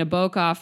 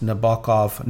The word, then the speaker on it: Nabokov: Nabokov for some image I was working on or something.